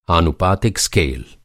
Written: आनुपातिक

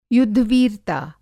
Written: युद्धवीरता

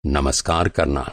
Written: नमस्कार

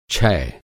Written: छै

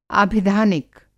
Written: आभिधानिक

